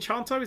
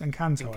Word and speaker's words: Encanto: Is it Enchanto? Is it Encanto? Is it Encanto? I don't know what it's called. – It's Encanto. I think so. It's pronounced.